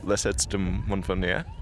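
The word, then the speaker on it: Lacets de Montvernier: That was cool, the La Sette de Montvernier. How was my pronunciation on that? – Nailed it.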